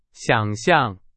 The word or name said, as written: 想象